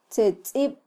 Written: tzetzib

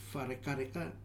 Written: Fare Kareka

